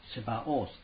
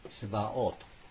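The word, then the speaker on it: Sabaoth: 実際このヤウェ・サバオース、セバオースっていうんですね。ヤウェ、セバオートとも発音されますが、このセバオートっていうのは万軍とかですね、万将とかいうふうに訳したりします。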